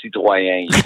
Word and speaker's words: Citroën: If you're going to fucking name a French car, could you please pronounce it right? It's Citroën.